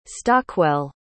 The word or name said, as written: stockwell